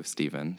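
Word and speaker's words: Stephen: Or my real name's there too, Steven, so...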